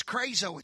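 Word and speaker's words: krazo (κράζω): It's a word, I don't know, for lack of better pronunciation, it's krazo. It's K-R-A-Z-O, which meant to shriek or to scream.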